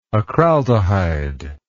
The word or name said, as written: acraldehyde